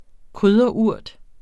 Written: krydderurt

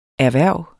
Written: erhverv

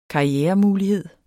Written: karrieremulighed

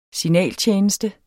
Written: signaltjeneste

